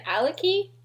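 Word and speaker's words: Alaki: Alakai? How did you say it? Oh, I was saying Aliki. – Aliki? Aliki, that sounds better.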